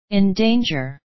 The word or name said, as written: in danger